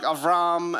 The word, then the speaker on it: Avram: I've been using pronunciation like Avram and Lot and stuff like that instead of Abraham and Lot. The reason is just because I'm a Bible nerd and I like to pronounce it the way that it's pronounced in Hebrew.